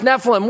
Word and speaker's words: Nephilim: Nephilim here, the Hebrew word right here, pronounced Nephilim.